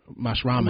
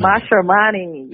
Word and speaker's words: Mashramani: You talk about in the book this this festival called, if I'm saying it right, Mashrami, Mashramani. – Mashramani, yes.